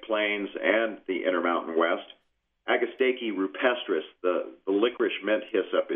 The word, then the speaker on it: agastache: One of your specialties, I've noticed, is that, is it pronounced agastache? – Yes, you can pronounce it agastache, agastache, agastache. – Okay. – A lot of ways to pronounce it, but if you come into any nursery that's familiar with native plants, we'll know what you're talking about.